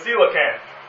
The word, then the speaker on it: coelacanth: The next thing that they found, or they thought was extinct, was a fish called the coelacanth. Now, it sounds, I'll put this in, it sounds like this. Coela, like in the Bible, rest, Sela. Can't.